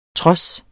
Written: trods

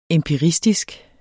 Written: empiristisk

